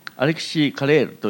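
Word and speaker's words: Alexis Carrel: アレキス・カレルは、フランス人ですからアレキシー・カレールと読むのが正しいという人もいますが、この人長い間アメリカにおりまして、ロックフェラー研究所にいる頃にノーベル賞を待ってますので、英語読みにしてアレキス・カレルと言ってもいいと思います。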